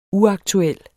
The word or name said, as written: uaktuel